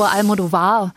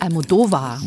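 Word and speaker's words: Almodóvar: Wir sagen immer Pedro Almodovar, aber wir haben jetzt gelernt, Knut, nicht wahr? Der heißt Almodovar. – Wo der Strich ist, ne? – Wo der Almodovar.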